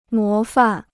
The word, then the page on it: 模范: mó fàn